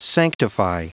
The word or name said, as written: sanctify